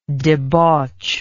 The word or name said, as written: debauchment